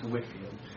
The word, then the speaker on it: Whitefield: It's spelled Whitefield, but from what I've read, it's pronounced Whitefield.